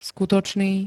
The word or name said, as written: skutočný